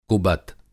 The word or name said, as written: कुबत